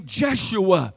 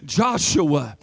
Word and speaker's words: Yeshua: Well, if you go to the Old Testament, in the Hebrew, they pronounced his name Jeshua or Yeshua. Or in your Bibles, you will read it Joshua. Joshua.